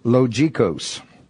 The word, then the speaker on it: logikos: It's not logos, it's logikos. And you spell it L-O-G-I-K-O-S.